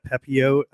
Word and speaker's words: Pepiot: Ryan, Pepiot, I think is how you say his last name. And I'm gonna agree with that. Pepio. I've been doing it without. – I think you're right. It's Pepio.